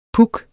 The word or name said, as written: puck